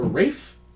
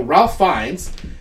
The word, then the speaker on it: Ralph Fiennes: I think it's pronounced Ray or Rafe. – Oh, okay. – Yeah. When I see it written, I go, Ralph Fiennes. And then I hear people say it, like, on shows, and I don't feel like they're saying what I'm saying.